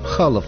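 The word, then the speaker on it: Chaloff: Конечно, его фамилия пишется через два F, а русское произношение на российских сайтах Серж Халов.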